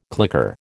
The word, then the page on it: claqueur: American English